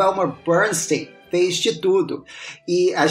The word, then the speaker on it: Bernstein: E tanto que, para diferenciar no mundo musical, o Elmer que, o, cujo nome a gente pronuncia Bernstein, ele era conhecido como Bernstein do Oeste, por causa de Hollywood. E o Leonard Bernstein, pronuncia-se, no caso dele, Bernstein, era conhecido como o Bernstein do Leste.